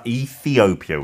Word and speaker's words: Ethiopia: 아, Ethiopia we say in English. Ethiopia. Mm-hmm. I got it.